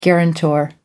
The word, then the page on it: guarantor: American English